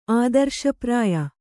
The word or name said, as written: ಆದರ್ಶಪ್ರಾಯ